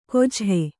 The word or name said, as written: ಕೊಜ್ಹೆ